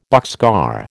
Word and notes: boxcar: American English

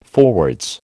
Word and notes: forwards: American English

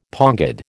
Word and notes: pongid: American English

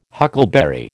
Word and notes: huckleberry: American English

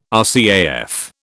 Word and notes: RCAF: British English

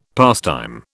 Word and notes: pastime: British English

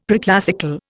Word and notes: preclassical: Indian

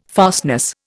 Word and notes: fastness: Indian

